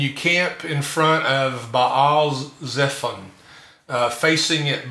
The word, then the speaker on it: Baal Zephon: We learned growing up to pronounce, say, Baal, you know, as the demonic god, but it's Baal and it's Zephon.